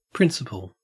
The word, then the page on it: principal: Australian English